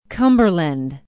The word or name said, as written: cumberland